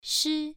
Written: shi1

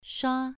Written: shua2

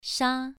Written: sha2

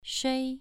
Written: shui2

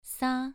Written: sa2

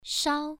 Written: shao1

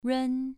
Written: run2

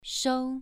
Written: shou2

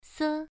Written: se1

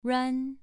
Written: ruan2

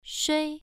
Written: shui1

